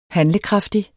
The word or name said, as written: HANDLEKRAFTIG